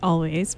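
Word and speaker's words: Alvvays: I pronounce it Always, but they spell it with two V's. Betty Davis Eyes by Kim Carnes, a classic, which I maybe play a little too much on the air.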